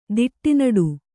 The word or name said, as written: ದಿಟ್ಟಿನಡು